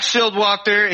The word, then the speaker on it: Shildwachter: Yeah, so we got Zach Shildwachter.